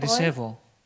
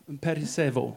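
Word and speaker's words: Perisevo (περισσεύω): Do you know how to pronounce that better? – Wisst ihr, wer das besser aussprechen kann, unser griechischer Freund? – Perisevo. Again? Perisevo. – Perisero. Danke. – Like vo. Perisevo.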